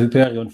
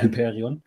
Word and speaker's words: Hyperion: Ich finde das ein bisschen affig, dass ich da jetzt so einen komischen Namen, den ich gar nicht aussprechen kann. – Hast du kannst Hyperion nicht aus. Ja, ich heiße Hyperion für dich, bitte. Oder für dich heiße ich Hyperion.